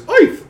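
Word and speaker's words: Oerth: Although I guess the Gygaxian pronunciation is Oif. Like you're Yiddish. But I say Orth because it's less silly.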